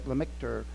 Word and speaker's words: Lemaître: This was really first developed by Georges Lemaître, that's how you say it, Lemaître.